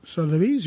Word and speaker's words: Sotheby's: Sotheby's, yeah.